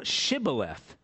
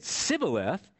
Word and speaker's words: Shibboleth: Then they would say to him, "Say now Shibboleth." But if he said Sibboleth, he could not pronounce it correctly.